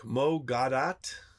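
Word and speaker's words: Mo Gawdat: In this book, Mo Gadat, that's how you say it, he wrote an equation.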